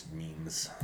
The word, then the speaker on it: meme: And we like those. Is that how they're pronounced? – It's meme, yes.